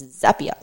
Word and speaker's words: Zapier: It's Zapier. Like, this is how you say our name.